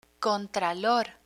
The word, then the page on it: contralor: Spanish